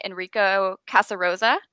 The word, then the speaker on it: Enrico Casarosa: Yeah, so it's directed by Academy Award nominee Enrico Casarosa.